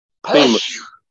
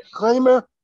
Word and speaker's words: Klima: Klemer. – Hmm? How do you say it? – Klemer. – Peter. Klemer. P-E-T-R. So I guess that's Peter. – Peter, yeah. – K-L-I-M-A.